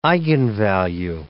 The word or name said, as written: eigenvalue